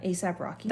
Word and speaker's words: ASAP Rocky: ASAP Rocky. – That's what I thought. ASAP Rocky. Like, ASAP Rocky. – Yeah. I was like, ASAP Rocky. And then I was like, oh, maybe it's ASAP Rocky.